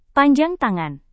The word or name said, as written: panjang tangan